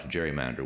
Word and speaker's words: gerrymander: We pronounce gerrymander with a soft G.